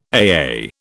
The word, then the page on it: AA: British English